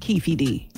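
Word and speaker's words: Keffe D: His name is pronounced Keefy D. – Oh, Keefy D. Okay. He needs to spell it different. Because that's a silent E. It's after a consonant.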